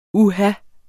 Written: uha

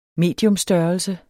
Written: mediumstørrelse

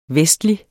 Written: vestlig

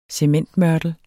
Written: cementmørtel

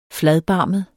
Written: fladbarmet

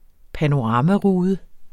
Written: panoramarude

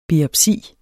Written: biopsi

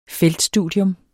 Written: feltstudium

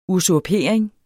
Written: usurpering

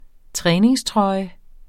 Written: træningstrøje

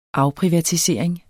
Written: afprivatisering

